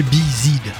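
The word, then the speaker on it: B'z: Ils se font appeler Bizid. Mais on prononce biz, comme des hyménoptères anglo-saxons.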